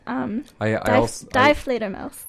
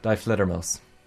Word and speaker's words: Die Fledermaus: My mom told me how to say it, but Die Fledermaus. – I work with a German fellow, and he told me as well it was... Die Fledermaus.